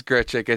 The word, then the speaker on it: Grichuk: It is Grichuk.